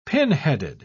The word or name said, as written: pinheaded